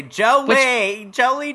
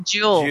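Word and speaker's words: Joule: Well, I was going to say Jolie Dallas, 3-night stay at the Jolie Dallas Hotel. And, of course, the Dallas Convention and Visitor Center. Oh, he said Jewel. – Oh, he said that's pronounced Jewel.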